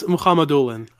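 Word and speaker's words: Mukhamadullin: It's Mohamedoulin. I have the luck of being Arab, and he's from a region that is Muslim in origin.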